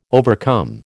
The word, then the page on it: overcome: American English